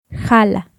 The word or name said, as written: Jala